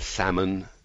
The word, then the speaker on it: salmon: The silent P in receipt, the silent B in subtle, the silent L in salmon, and so on.